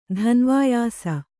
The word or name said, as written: ಧನ್ವಾಯಾಸ